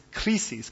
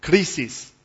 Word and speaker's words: krisis: The word is krisis. Can you try the word krisis? If we were to spell it in English letters, it would be K-R-I-S-I-S.